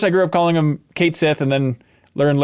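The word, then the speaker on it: Cait Sith: is pronounced Kate Sith and not the Scottish Gaelic pronunciation Ketchi.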